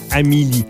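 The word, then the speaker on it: Amylie: Mais elle, c'est plus parce que son nom s'écrit Ami, A-M-Y-L-I-E, c'est ça? Amélie. – Oui. – Les Amélie, eux autres, ça marche pas le jeu de monde. – Non, c'est ça, c'est pas un ami.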